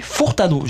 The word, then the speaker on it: Furtado: On a Nelly Furtado.